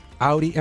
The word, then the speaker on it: Audi: Do Audi.